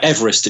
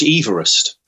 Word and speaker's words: Everest: Incidentally, he called himself George Everest. That's the way he pronounced his own name like that. So technically we should say Mount Everest.